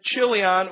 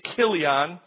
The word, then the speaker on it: Chilion: He had two sons named Mahlon and Chilion, or in Hebrew you could say Kilion, for those of you who are into pronunciation. And what are his sons named for?